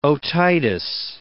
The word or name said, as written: otitis